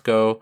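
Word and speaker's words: Go (五): The title in Japanese is Mach Go Go Go, and Go is kind of a pun on the number five is pronounced Go in Japanese.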